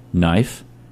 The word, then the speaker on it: knife: Knife, K is silent.